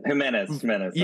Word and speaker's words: Jimenez: And it's Mencken versus, I'm going to butcher his name, like, Jimenez Velasquez? Or something like that. – Jimenez. – Yeah, that's it.